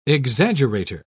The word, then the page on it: exaggerator: British English